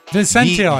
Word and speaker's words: Vicentino: And Camisano Vicentino, Vicentino, I think, yeah. – What is it? – With a soft C. I think you had it right.